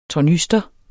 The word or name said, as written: tornyster